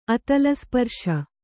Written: ಅತಲಸ್ಪರ್ಶ

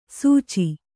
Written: ಸುಚಿ

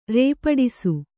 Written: ರೇಪಡಿಸು

